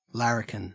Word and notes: larrikin: Australian English

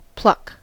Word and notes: pluck: American English

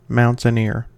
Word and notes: mountaineer: American English

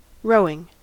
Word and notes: rowing: American English

